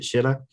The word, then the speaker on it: Schiller: Did I say that right? Is it Schiller? – It is right. Yes, that's right.